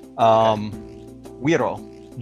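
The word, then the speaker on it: güiro: Incidentally, I should correct my pronunciation there. So like Güero is a blonde person. Güero, G-U-I-R-O. Is in fact the scraper instrument.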